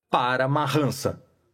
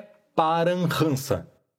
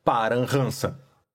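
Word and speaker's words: Paramahansa: Em outras palavras, as pessoas falantes de língua portuguesa, ou muito provavelmente qualquer não indiano médio, pronuncia assim esta palavra: para marrança. Entretanto, a pronúncia correta é paranhança. Para os ocidentais que simplesmente desejam tomar conhecimento, com certo grau de exatidão, de que modo pronunciar essa difícil palavra, convém dizer que os eruditos em sânscrito aceitam a pronúncia paranhansa.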